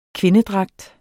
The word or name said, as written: kvindedragt